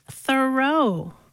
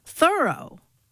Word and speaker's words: Thoreau: All this time, I thought his name was pronounced Thoreau, but no. – How is it pronounced then? – Thoreau.